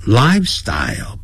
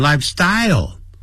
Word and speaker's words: lifestyle: 好，lifestyle 不要念成 lifestyle，重音在第一个音节。 style，因为有 L 结尾，所以舌尖要抵上来，想要发出欧洲的欧音 style。